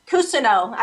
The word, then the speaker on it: Cousineau: Cousineau.